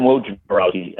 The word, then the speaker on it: Wojnarowski: I'm nervous about, you know, Adrian Wojnarowski, and I pronounced it correctly. One of my pet peeves is, can we get these ESPN, everybody on ESPN, to do a Woj pronunciation study? Because it's always Adrian Wojnarowski. You get it right.